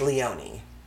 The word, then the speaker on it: Leone: So, let's talk about Leonie Jensen or Leone Jensen. The name is spelled L-E-O-N-E. – Mm. – Yeah, it's difficult. I went to school with a guy whose last name was L-E-O-N-E, and it was pronounced Leonie.